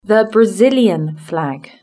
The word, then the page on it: the brazilian flag: British English